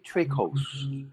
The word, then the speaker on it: Trakos: So Trakos or Trakos. – That's right. That's right. Yes. Well, the actual correct pronunciation is Trakos, but Americans say Trakos, which I think sounds so much better.